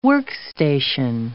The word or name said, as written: workstation